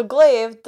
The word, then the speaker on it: glaive: So glaive, the silence glaive, everyone, which is pronounced the way that it's spelled and exactly the way that you would think it's pronounced in the 90s dub from Dick/4Kids.